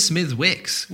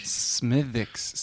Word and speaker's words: Smithwick's: No, he says Smithwick's. Wait, what? – No, he says silent W.